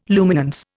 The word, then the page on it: luminance: Indian